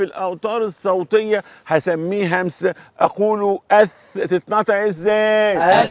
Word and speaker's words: ث: أث. – يبقى لولا الهمسة الذي في الثاء لكان الذال، ولولا الترقيق الذي في الثاء لكان الظاء، يبقى اهي أث أث، ولا تنطق الثاء سين هكذا، ودايما تتصبحوا بي وتتمسوا بي، يرفعكم الله أعلى الدرجات.